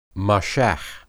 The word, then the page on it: mashah: Hebrew